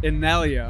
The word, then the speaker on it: Innellea: Did I say it right? – Almost. – I've been saying it with a Spanish. I'm so sorry. You tell them how to pronounce, because I'm bad with pronunciations. – It's pronounced Innellea.